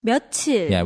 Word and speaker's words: 며칠: Yeah, I think it used to be 몇 plus 일, but a lot of people also started pronouncing this 몇 plus 일 in a different way, mm-hmm. – and it kind of evolved into 며칠.